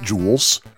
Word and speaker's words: Jewel: The Jewel, or as we call it in Chicago, Jewels, J E W E L is pronounced Jewels, by the way, occasionally has blackberry ginger ale.